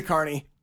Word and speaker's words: Kearney: His name is Matt Carney or Matt Kearney. I don't really know how to say his name. – Oh, I like that so. – Guy. – Kearney? Carney? He's a Carney.